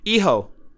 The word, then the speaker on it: Hijo: Jeff, the H is silent. – Oh. – Hijo.